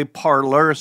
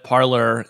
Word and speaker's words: Parler: As best as I can tell, the creators call it Parler, and I do find it very difficult that a network for American conservatives would brand itself with a French language pronunciation. So I'm sticking with Parler.